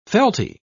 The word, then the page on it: felty: British English